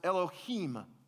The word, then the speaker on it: Elohim: It's pronounced Elohim.